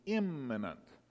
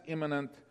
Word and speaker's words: immanent: That's spelled E-M-I-N-E-N-T. Eminent, as opposed to imminent. Clear difference in pronunciation. However, there's a trickier word, which as far as I can tell is pronounced exactly the same. And that's imminent, I-M-M-A-N-E-N-T. The only difference is that this word is spelled with an A in the middle rather than an I, pronounced imminent, exactly the same.